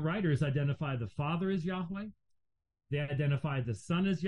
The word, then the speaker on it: Yahweh: Now, in most English translations, they just have the word Lord, but they put it in capital forms, L-O-R-D in capital forms. But in the Hebrew language, that is the what's called the Tetragrammaton, Yahweh. We sometimes slaughter that as Jehovah in English. It could not have been pronounced that way, but be that as it may.